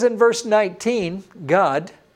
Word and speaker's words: Gad: No, it's just that that A is pronounced like the A in father. It's an A sound, not an A sound. Just like it was in Dan, it's not Dan, it's Don. In G-A-D, the Hebrew name that you would call Gad if you'd pronounce it in English, is pronounced Gad in Hebrew. He says in verse 19, God, a troop shall ramp upon him, but he shall triumph at last.